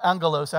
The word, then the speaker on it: angelos: The Greek word is angelos, and or angelos, actually, it's a hard G.